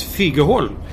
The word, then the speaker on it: Figeholm: Det stavas Figeholm, men det uttalas Figeholm.